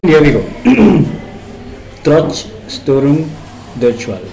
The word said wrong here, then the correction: Trotz Störung Durchfall: Am I saying it correct? – No. – please, can you say it correctly? – Yes, it's Trotz Störung Durchfall.